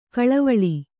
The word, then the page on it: ಕಳವಳ: kaḷavaḷa